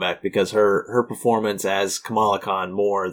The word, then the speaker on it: Kamala Khan: Is that her name? – Kamala Khan. Yes. That's right.